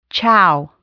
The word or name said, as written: chow